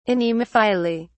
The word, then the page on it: anemophily: British English